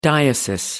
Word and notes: diocese: American English

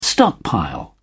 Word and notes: stockpile: British English

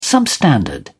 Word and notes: substandard: British English

